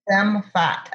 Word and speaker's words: Femme Fat: Did you ever, I'm curious about, and I apologize if I mispronounce it, but is it Femme Fat Entertainment Group? – Yes, Femme Fat, as in Femme Fatale.